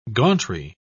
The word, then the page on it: gauntry: British English